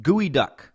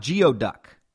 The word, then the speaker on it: geoduck: That's a cockle, and it's pronounced gooey duck, not geoduck.